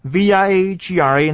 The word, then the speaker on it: Viagra: 注意啊，这很多人会念错的，V I A G R A 那个 I 要念的完整的 I 的声音，然后重音摆在 A 的位置，念作 Viagra。